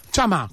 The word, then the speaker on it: Chama: ¿Cómo se pronuncia bien tu apellido? ¿Chama? – Chama. – Como está escrito.